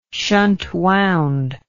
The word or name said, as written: shunt wound